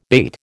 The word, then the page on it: bate: American English